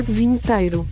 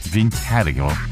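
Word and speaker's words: Vinheteiro: How do you pronounce his name? Vinitero? Lord Vinitero? Let me see if there's a pronunciation guide on this one. Search. Okay. Vinitero. That's how he says it.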